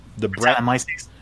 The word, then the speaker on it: Brettanomyces: The Brettanomyces.